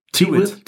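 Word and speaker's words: Tyrwhitt: der hat Hemden bestellt von Charles, wie spricht sich auch Tyrewith? Tyrewith. – Tewit. Tewit. Mhm.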